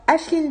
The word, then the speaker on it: Aisling: Alors, Ashlyn B, ça s'écrit A-I-S-L-I-N-G et le nom de famille c'est Bibea. Euh, c'est pour ça qu'on a hésité sur la prononciation. C'est une euh, prononciation particulière parce que c'est un nom irlandais.